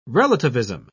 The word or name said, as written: relativism